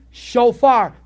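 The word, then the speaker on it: shofar (שופר): Shofar.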